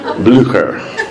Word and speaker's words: Blücher: Blücher.